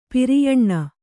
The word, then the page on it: ಪಿರಿಯಣ್ಣ: piriyaṇṇa